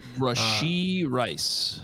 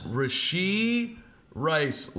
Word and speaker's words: Rashee Rice: Rashee Rice. – Rashee Rice, ladies and gentlemen.